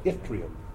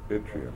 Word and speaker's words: Yttrium: How is it pronounced? – Yttrium. – Yttrium. – Mm-hmm. Like it was an I.